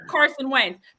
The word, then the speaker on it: Carson Wentz: That's how you say his name, because there are a number of teams that Carson Wentz could go to right now, this second, this moment, and be the starting quarterback.